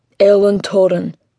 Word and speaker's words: Eilean Torren: I can't quite pronounce it. – Eilean Torren. Scots Gaelic.